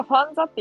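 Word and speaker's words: FANZA: これがファンザって読むことを知らんだわ。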